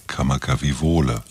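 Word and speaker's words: Kamakawiwole (Kamakawiwoʻole): Israel Kamakawiwole.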